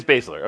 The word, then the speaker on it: Baszler: How do you say Baszler. – Her last? It's Baszler. Okay.